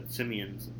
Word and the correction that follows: Semien: You know how to pronounce his name. It's Marcus Semien.